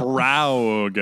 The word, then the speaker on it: Sprague: Sprague Graydon, which is an incredible name, is the Sprague. – I mean, I'm guessing that's how you say it. It's spelled like Prague, but there's an S at the beginning.